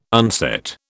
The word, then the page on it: unset: British English